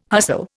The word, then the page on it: hustle: Indian